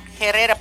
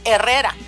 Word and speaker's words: Herrera: Juan Herrera, Herrera, Herrera, pero no se debe pronunciar el H, Herrera, Herrera. So, y luego también, como les dije hace ratito, la J, pues como la el nombre Jiménez, aquí los maestros dicen Jiménez.